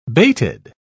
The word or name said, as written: bated